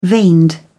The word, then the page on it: veined: British English